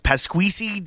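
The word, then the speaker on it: Pasquesi: I believe it's pronounced Pasquisi, David Pasquisi, who's Selena's husband on Veep.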